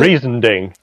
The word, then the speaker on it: Riesending: Riesending.